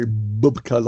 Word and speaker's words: Bubka: I have listened to some of yours, some of Ian's, and some of Les Bubka's. – Yeah. – Mm-hmm. – And you have to say Bubka like that.